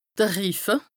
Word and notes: de Riffe: Bas-Rhin; Strasbourg

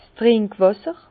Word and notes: 's Trinkwàsser: Bas-Rhin; Herrlisheim

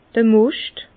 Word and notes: d'r Moscht: Bas Rhin; Herrlisheim

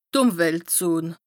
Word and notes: d' Ùmweltzon: Bas Rhin

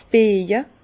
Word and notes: 's Bieja: Bas Rhin; Herrlisheim

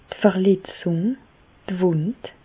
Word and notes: d' Verletzùng, d' Wùnd: Alsatian; Bas-Rhin; Reichshoffen

